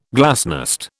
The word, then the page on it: glasnost: British English